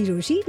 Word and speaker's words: Rochie: मेरा नाम है मिर्ची रोशी, नाम की स्पेलिंग है ROCHIE, and I promise you there is no judgement here.